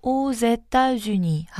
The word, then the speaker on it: aux États-Unis: 그래서 익스도 S 발음이 있기 때문에 연음해서 오제따주니 하고 읽어줍니다.